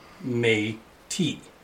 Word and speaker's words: Métis: Just in case you didn't know, there's a significant difference between the Metis, phonetically spelled Met-is, and the Metis, phonetically spelled May-T.